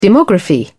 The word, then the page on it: demography: British English